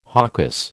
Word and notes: hocus: American English

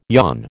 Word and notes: yawn: American English

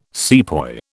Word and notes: sepoy: British English

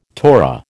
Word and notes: Torah: American English

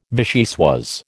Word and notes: vichyssoise: American English

